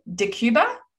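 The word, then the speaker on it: Decjuba: Is it DeCuba? – Yeah. That's how you say it.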